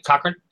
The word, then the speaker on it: Cochrane: Cochran? C-O-C-H-R-A-N-E.